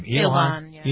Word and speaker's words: Ilhan: How do you pronounce her name? – Ilhan.